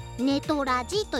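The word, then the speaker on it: NTRじ: これはですね、 NTR がアルファベット大文字で、うんうん、G がひらがなでネトラジと読みます。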